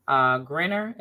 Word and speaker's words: Griner: you have the sports commentators asking, now let's get Brittany Griner, is that how you say her name? – Yeah.